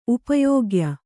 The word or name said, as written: ಉಪಯೋಗ್ಯ